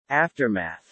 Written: aftermath

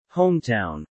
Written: hometown

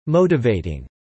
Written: motivating

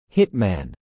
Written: hitman

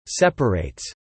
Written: separates

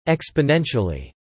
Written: exponentially

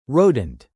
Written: rodent

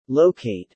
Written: locate

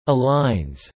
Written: aligns

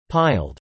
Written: piled

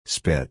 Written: spit

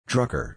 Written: Drucker

